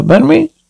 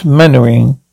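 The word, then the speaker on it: Mainwaring: Mannery? Do sit down, Colonel. Thank you. Actually, it's pronounced Mannering. Why the devil do you spell it Mannering?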